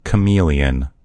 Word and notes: chameleon: American English

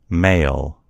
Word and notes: mail: American English

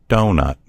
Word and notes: doughnut: American English

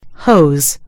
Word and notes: hose: American English

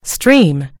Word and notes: stream: American English

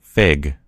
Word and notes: fig: American English

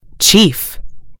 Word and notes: chief: American English

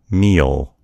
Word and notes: meal: American English

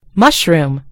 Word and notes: mushroom: British English

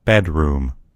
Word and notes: bedroom: American English

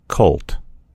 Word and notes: colt: American English

